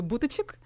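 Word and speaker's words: Buttigieg: І половина людей, з якими я спілкувалася, які спробували вимовляти його ім'я, говорили «Бутичик», а інші люди просто говорили «Марпіт».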